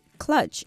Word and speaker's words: kludge: That is clutch, and it is spelled K-L-U-D-G-E. That's K-L-U-D-G-E, clutch.